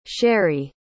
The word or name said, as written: sherie